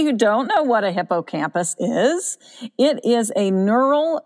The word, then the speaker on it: hippocampus: Birds and mammals, yes, that includes humans, have what is called a hippocampus. – Yes, exactly. – Did I say that right? – Just perfect.